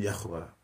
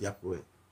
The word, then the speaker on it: Yahweh: The actual name, which is Yud-Heh-Vav-Heh, referred to as the Tetragrammaton, this is a four-letter name, really is the name Yahweh, or some persons pronounce it Yahweh.